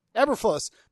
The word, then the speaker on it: Eberflus: Is it Eberflus? Eberflus? How do you say it? You're a pronunciation guy. Did I get it right the first time? – Eberflus. – Matt Eberflus' defense is playing really well.